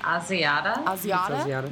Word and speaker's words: Asiata: I've got Matt Hazada. Is that pronouncing it? Why not? – Asiata? – Yeah, that sounds better.